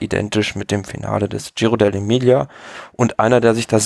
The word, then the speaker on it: Giro dell'Emilia: Fangen wir doch mal einfach an mit dem Giro dell'Emilia.